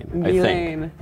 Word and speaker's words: Ghislaine: Ghislaine. – Okay, so maybe I've been saying Ghislaine. Okay. – Ghislaine. – I just need to get rid of the S altogether.